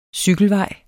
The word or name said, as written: cykelvej